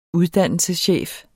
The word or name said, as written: uddannelseschef